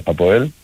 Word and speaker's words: ΑΠΟΕΛ: Εντάξει, όχι πολλά πράγματα, όχι πολλά παραπάνω από αυτά που είπαμε και χθες και προχθές ουσιαστικά, τη μέρα του αγώνα της ΑΠΟΕΛ. Έχω λες και τη λέω ΧΑΠΟΕΛ τώρα, επειδή τη λέμε στο Ισραήλ ΧΑΠΟΕΛ. – Ναι. – Συντονίζουμε στο βίντεο, τη λέω και εγώ συνέχεια έτσι.